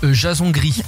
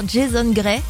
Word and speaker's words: Jason Gray: Et aujourd'hui, c'est un certain Jason Gris. – Oui, alors je suis pas sûre que lui-même le prononce comme ça. – C'est sûr que non, clairement. – On va faire un petit mélange, on va dire Jason Gray.